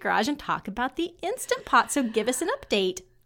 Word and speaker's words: Instant Pot: Well, one of the first things I need to do in my update is correct my pronunciation of the appliance back in episode 18. That shows you how little I knew. At that point, I was calling it an Instapot.